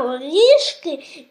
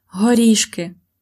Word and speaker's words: горішки: For example, the pronunciation of r, r in горішки.